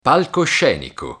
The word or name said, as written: palcoscenico